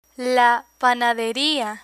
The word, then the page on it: panaderia: Spanish